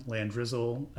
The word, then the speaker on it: Landrizzle: Uh, Twitter and Instagram, Landrizzle, L A N D R I Z Z L E.